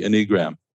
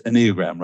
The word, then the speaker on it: Enneagram: Enneagram. So accent on the first syllable.